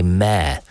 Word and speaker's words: mayor: And to Christine, who has said that please pronounce it correctly. They are not female horses or donkeys. Mayor has two syllables. I think I do say mayor, don't I?